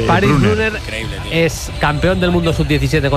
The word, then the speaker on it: Brunner: Paris Brunner, con dos N's, Brunner.